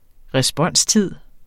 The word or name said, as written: responstid